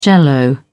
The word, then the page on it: jell-o: British English